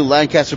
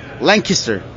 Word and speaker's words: Lancaster: and I just moved to Lancaster, Pennsylvania. Wait, hold on, sorry. Lancaster. So I have to say it right, otherwise I get a lot of stuff back.